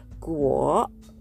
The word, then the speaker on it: 国: 2 つ目の国という文字、これはグオと読みます。